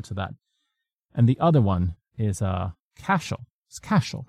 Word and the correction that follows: Cassiel: right? Is that how we say pronounce it? – Cassiel. – Is it Cassiel? All right. – Cassiel.